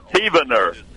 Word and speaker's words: Heavener: It's spelled heaven with an E-R on it, but it's pronounced Heavener, Oklahoma. – A bunch of heathens. – That's right. Heavener.